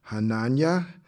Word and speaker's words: Hananiah: Among these from the people of Yehuda were Daniel, Hananiah, Mishael, and Azariah. That's how you pronounce their name in Hebrew.